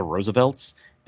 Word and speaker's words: RSVLTS: So the Roosevelts is this clothing company on the internet that is it's spelled R-S-V-L-T-S. So it looks like results, but apparently it's pronounced the Roosevelts.